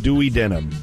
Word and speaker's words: duodenum: How do you spell it? – D-U-O-D-E-N-U-M. My science teacher when I was in high school called it duodenum. And I know that's not right. It's Dewey Denim.